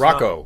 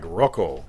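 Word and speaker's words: Rakau: Raquel. – Only it's Rocco. Not, Rocco. – It's like Rocco. – It's like Rocky.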